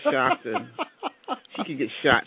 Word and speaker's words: Coshocton: I never know it's pronounced Coshocton. – No, it's Coshocton.